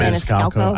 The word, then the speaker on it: Maniscalco: I never say Maniscalco. – I said it wrong for the first 10 years.